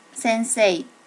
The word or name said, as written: sensei